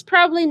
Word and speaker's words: probably: So she starts by tranquilizing Susie, saying that it's probably nothing serious. It's probably not serious. Let's repeat. It's probably. Watch out for the pronunciation of probably. The stress is in the first syllable.